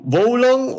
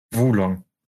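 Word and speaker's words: Wo Long: Wulong. – Wulong. Okay. – Ein weiches Wulong. W und O. – Wu. – Ja. – Wu, wie der Wu-Tang-Clan, ja.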